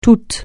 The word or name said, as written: tout